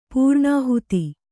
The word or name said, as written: ಪ್ರಣಿಹಿತ